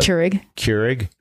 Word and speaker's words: Keurig: I pop in my Keurig. How do you pronounce that? – Keurig. Yeah, you said Keurig.